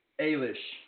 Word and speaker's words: Eilish: Eilish.